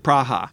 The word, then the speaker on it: Praha: But we did get news from one of our listeners in Praha that the Brahas do, in fact, pronounce it Praha.